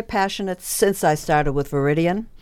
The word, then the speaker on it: Viridian: I'm your host, Nicole Perry, and she is with Viridian Energy. Is that how you say it? – Yes.